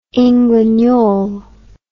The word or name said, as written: englanol